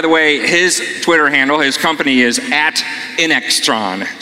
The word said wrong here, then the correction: Intrexon: just like it sounds. Intrexon. I'm saying it wrong. – Thanks. – At Intrexon. And if you're into biology, you'll notice it's a combination of intron and exon, which is something we may understand.